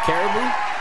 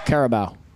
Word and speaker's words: Carabao: How do you pronounce that cup? Caribou? – Carabao. – Carabao. – The Carabao Cup, yes.